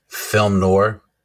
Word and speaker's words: film noir: And on this episode, we are covering the 1991 uh, horror drama comedy no nora. How do you say that word? – Film noir yeah, that thing.